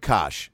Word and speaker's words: Koch: It's K-O-C-H. Koch? Jim Koch. How many times has this happened to this guy, by the way? Where he's like, it's Jim Koch. And they go, it's spelled cock. He goes, it's not.